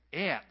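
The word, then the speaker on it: Etz (עץ): In fact, as I was preparing for this sermon, I read that the Hebrew word for tree is Etz, E-T-S, rhymes with gets.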